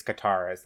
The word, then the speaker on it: Katara: It's Katara's then.